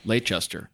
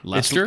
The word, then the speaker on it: Leicester: Leicester. – I believe it's pronounced Leicester. – Leicester? – Yeah, Leicester. – It is Leicester? – It's like Worcestershire.